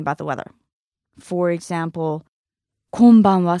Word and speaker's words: konbanwa: And finally, we have the evening greeting, which is konbanwa, konbanwa, konbanwa.